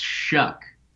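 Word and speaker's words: Shough: It's pronounced Shuck.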